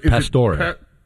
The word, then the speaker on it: Pastore: Pastore.